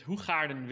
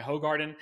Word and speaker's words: Hoegaarden: I am drinking a beer from Belgium named Hoegaarden Wit in the original Dutch pronunciation. In the US, I think it goes by Hogarden.